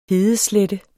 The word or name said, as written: hedeslette